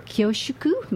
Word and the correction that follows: kyushoku: am I saying that right? – Kyushoku, is admired the world over.